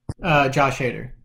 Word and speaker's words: Hader: Is it pronounced Hader? Hatter? – Josh Hader.